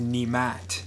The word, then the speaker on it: Nemet: Well, my grandfather, who the last name originally belonged to, is from Hungary, and I've been told that it's actually pronounced Nemat, or something along those lines.